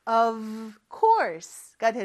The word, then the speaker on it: of course: 근데 저 of course라고 하지 않고 F를 V 발음으로 해주셔야 돼요. V 발음을 할 때는 윗니를 아랫 입술을 살짝 댄 상태에서 V라고 하는 것처럼 진동을 시켜줘요. 요렇게 그거를 아주 짧게 해주는 발음입니다. 그래서 of course가 되는 거죠.